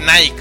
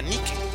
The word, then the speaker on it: Nike: Siempre llega consigo un báculo sagrado que simboliza la diosa de la victoria, Nike, que es lo mismo que Nike, güey. Nada más que los gringos pronuncian Nike, pero esto está en griego y en griego se pronuncia Nike o Nike, con acento en la E, Nike.